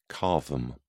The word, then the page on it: cavum: British English